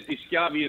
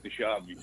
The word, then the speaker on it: DiSchiavi: It is DiSchiavi in Italy, but in America we pronounce it DiSchiavi.